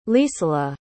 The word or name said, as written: lisala